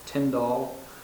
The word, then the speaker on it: Tyndall: And in the 1900s, a couple of people, British physicist Tyndall I think is how you pronounce it.